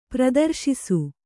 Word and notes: ಪ್ರದರ್ಶಿಸು: Kannada; pradarśisu

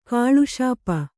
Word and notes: ಕುಲೆಸೊಪ್ಪು: kulesoppu

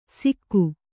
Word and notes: ಸಿಕ್ಕು: sikku

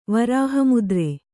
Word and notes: ವರಹಮುದ್ರೆ: varaha mudre

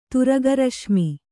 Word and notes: ತುರಗರಶ್ಮಿ: turaga raśmi

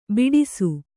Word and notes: ಬಡಿಸ: baḍisa

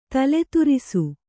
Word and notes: ತಲೆತುರಿಸು: tale turisu

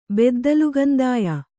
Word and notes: ಬೆದ್ದಲುಗಂದಾಯ: Kannada

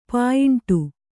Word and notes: ಪಾಯಿಂಟು: pāyiṇṭu

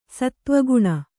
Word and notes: ಸತ್ತ್ವಗುಣ: sattva guṇa

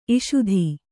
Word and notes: ಇಷುಧಿ: iṣudhi

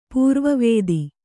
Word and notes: ಪೂರ್ವವಾದ: pūrva vāda

